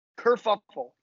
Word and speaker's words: kerfuffle: Kerfuffle. K-E-R-F-U-F-F-L-E.